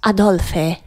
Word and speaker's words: Adolphe: Het is met PHE. – Dan moet je het wel uitspreken als Adolphe.